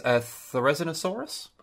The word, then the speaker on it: Therizinosaurus: Tyler said to answer some questions from previous episodes that Sniper asked, their favorite dinosaur is Therizinosaurus.